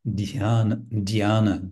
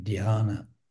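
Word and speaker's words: dhyana (ध्यान): And the word Chan is generally understood to be derived from the Sanskrit word dhyana, D-H-Y, long A-N-A, dhyana.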